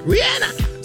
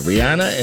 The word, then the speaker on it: Rihanna: And that's how she pronounces Rihanna, not Rihanna. I heard it from her when they asked, how do you pronounce it? Rihanna. So, yeah, Rihanna.